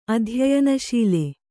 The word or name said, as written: ಅಧ್ಯಯನಶೀಲ